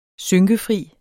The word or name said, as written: synkefri